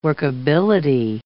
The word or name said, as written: workability